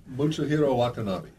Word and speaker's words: Mutsuhiro Watanabe: Mutsuhiro Watanabe.